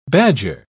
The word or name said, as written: badger